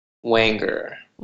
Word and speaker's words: Wanger: How do you say your last name? – Wanger. – Wanger. Okay. Perfect.